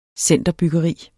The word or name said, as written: centerbyggeri